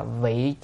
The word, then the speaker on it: vị: Mà bổn nguyện này là vị chúng sanh cố, chữ này đọc là vị nha, vị chứ không phải vi nha.